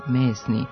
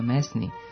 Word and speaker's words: mesni: Pridev, mesni, sa dugosilaznim akcentom na prvom slogu opisuje nešto u čemu ima mesa, koji sadrži meso, spravljanje od mesa ili sa mesom, koji se odnosi na meso uopšte.